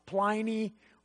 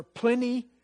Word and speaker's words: Pliny: We have records of people like one guy was by the name of Pliny, or Pliny, as some pronounce it, a Latin philosopher who was about this age.